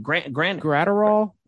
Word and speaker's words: Graterol: And always pronounce his name was Granerol. How do I pronounce his name? – Graterol.